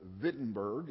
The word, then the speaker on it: Wittenberg: Spelled with a W. To us in English, it looks like Wittenberg. But in Germany, they pronounce a W like a V. And so It is literally Wittenberg in Germany.